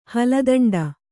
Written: ಹಲದಂಡ

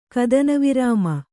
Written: ಕದನವಿರಾಮ

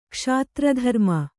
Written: ಕ್ಷತ್ರಧರ್ಮ

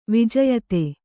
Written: ವಿಜಯತೆ

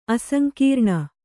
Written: ಅಸಂಕೀರ್ಣ

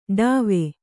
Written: ಟಾವು

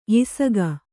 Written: ಯಿಸಗ

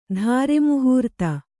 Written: ಧಾರೆಮುಹೂರ್ತ

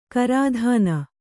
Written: ಗ್ರಥನ

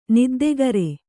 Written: ನಿದ್ದೆಗರೆ

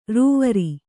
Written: ರೂವರಿ

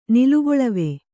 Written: ನಿಲುಗೊಳವೆ

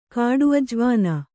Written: ಕಾಡುಅಜವಾನ

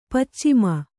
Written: ಪಚ್ಚಿಮ

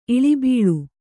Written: ಇಳಿಬೀಳು

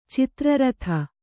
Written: ಚಿತ್ರಾರ್ಧ

